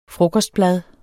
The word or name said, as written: frokostblad